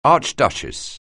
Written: archduchess

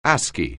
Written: ASCII

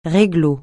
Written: réglo

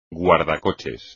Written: guardacoches

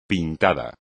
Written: pintada

